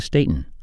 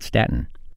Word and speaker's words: Staton: This is Dakota Staton, not Staten.